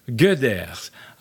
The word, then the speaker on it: Goeders: G-O-E-D-E-R-S. Est-ce qu'on prononce Gauders? – Gauders.